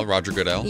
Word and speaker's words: Goodell: Roger Goodell.